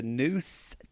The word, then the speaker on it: Noose: Not the New Titans. – I said the Noose Titans. – You just didn't pronounce the S as a voiced S. – The Noose Titans.